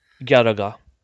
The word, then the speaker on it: Galaga: You can say Garaga. Right, just ga-ra-ga. – Ga-ya-ga. – You know, that's how you would pronounce it in Japanese.